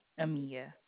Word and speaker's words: Amia: Amia. – Amia? – Yes, ma'am. – How do you spell that, please? – A-M-I-A.